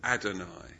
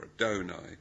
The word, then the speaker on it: Adonai: But in Psalm 86, where it's translated in our Bibles as Lord, it's the word Adonai, or Adonai, depending on whether you're American or English, how you pronounce that.